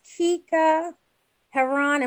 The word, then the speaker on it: Keka Heron: Miss Keka Heron, am I pronouncing it right? – Yeah, you're right, yeah.